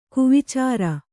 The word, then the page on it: ಕುವಿಚಾರ: kuvicāra; Kannada